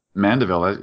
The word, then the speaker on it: Mandeville: It said you're from, is it Mandeville? Is that how you say it? – Mandeville.